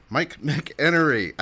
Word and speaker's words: McEnery: That's right, Mike McEnery. I've been calling Mike by the wrong pronunciation all this time, and I just got it straight.